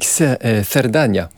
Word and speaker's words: Cerdanya: Cerdanya, puisque vous m'avez appris à le prononcer correctement, Alfonso, du compositeur issu de la Catalogne française, Deodat Sevrac.